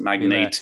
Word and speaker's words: magnate: Yeah, I think it's technically pronounced magnate. I think we mispronounce it, but it's magnate because there's an e at the end.